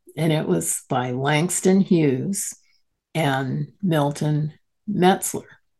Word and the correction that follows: Meltzer: Okay, Meltzer, I'm sorry, I said his name wrong.